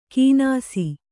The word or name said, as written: ಕಿನಾಸಿ